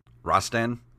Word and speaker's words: Rastan: Is it Rastan? Rastan. R-A-S-T-A-N? – Yeah.